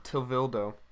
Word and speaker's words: Tevildo: Tevildu? Tevildu? – I think it's Tevildo. – Tevildo.